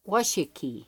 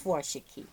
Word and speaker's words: Washakie: Park and Washakie. – Washakie. – That's how you say it? – Uh-huh. You bet. – Washakie.